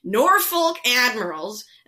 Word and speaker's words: Norfolk Admirals: But before you played any large amounts of hockey with the Blackhawks, you played with the get ready for me to butcher this, Norfolk Admirals, where you had almost... – Oh, you got it.